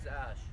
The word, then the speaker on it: Ash: My name is Ash. – Ash? – Yes. – And how do you spell it? – A-S-H.